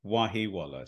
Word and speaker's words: Wahiwala: My name is Sukhi Wahiwala.